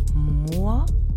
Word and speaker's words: Moor: Aber wenn du mal genau zuhörst, merkst du, dass sie doch ziemlich unterschiedlich ausgesprochen werden. Hör mal. Moor, Möhre.